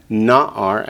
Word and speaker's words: Na'ar: Na'ar, accents the first syllable.